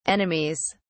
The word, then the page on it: enemies: British English